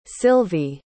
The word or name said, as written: Silvy